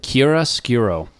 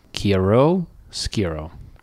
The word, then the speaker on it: chiaroscuro: Chiaroscuro. Chiaroscuro.